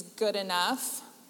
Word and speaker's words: Goodenough: I am Laura, and the last name is Good Enough. Yes, it really, really, really is. It's spelled exactly like it sounds.